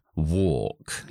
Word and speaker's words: walk: walk. Ya sé que es una A y una L. La L no se pronuncia y la A es como si fuera una O larga.